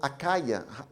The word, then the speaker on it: Achaia: Paul commends them because they received the gospel and now they are spreading the gospel to all the areas there on Achaia.